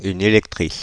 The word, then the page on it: électrice: [e.lɛk.tʁis]; Paris